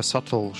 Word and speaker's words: subtle: Он настолько саттл, что буква B в нем прямо не произносится.